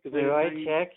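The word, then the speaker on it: Vujicic: So I, I've read of uh, Nick Verachek. – Verichek, yeah, yeah, yeah.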